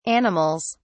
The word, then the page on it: animals: British English